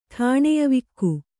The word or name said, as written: ಠಾಣೆಯವಿಕ್ಕು